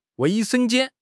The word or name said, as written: wei4 sheng1 jian1